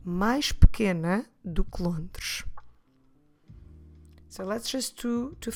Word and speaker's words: Londres: C-I-D-A-D-E. Cidade. Lisboa é uma cidade mais pequena do que Londres. Londres is the Portuguese word for London. Como se escreve Londres? L-O-N-D-R-E-S.